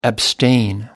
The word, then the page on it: abstain: American English